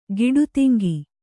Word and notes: ಗಿಡುತಿಂಗಿ: giḍugatiŋgi

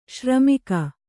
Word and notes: ಶ್ರೀಮುಖ: śrī mukha

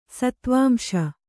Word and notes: ಸತ್ವಾಂಶ: Kannada

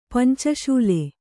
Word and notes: ಪಂಚಶೂಲೆ: panca śule

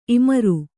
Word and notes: ಇಮುರು: imuru

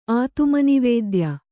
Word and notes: ಆತುಮನಿವೇದ್ಯ: Kannada

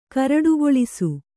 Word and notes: ಕರಡುಗೊಳಿಸು: kannada

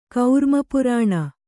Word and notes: ಕೌರ್ಮಪುರಾಣ: kaurma purāṇa

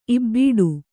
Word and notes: ಇಬಡಿ: ibaḍi

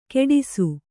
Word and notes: ಕೆಡಿಸು: keḍisu

